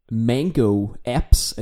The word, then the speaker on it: Mango Apps: Og derudover så er du medstifter af Mango Apps. Er det korrekt udtalt? – Det er fint. Jeg kan rigtig godt lide din øh, amerikaniserede udgave af udtalen, så det er fornemt.